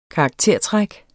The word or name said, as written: karaktertræk